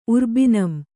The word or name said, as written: ಉರ್ಬಿನಂ